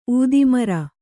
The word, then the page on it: ಊದಿಮರ: ūdimara; Kannada